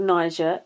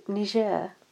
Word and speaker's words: Niger: It's not pronounced Niger, it's called Niger.